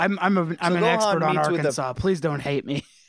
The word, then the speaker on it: Arkansas: No, 30% of Arkansas. – 30, just that silent S.